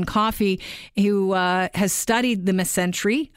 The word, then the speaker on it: mesentery: No, I think you got it right. – Mesentery. Mesentery. – Yeah. – Like dysentery, but it's a mesentery. – That's right.